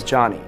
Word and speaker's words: Jony: Oh, my name is Johnny. – Johnny. – Spelled J-O-N-Y.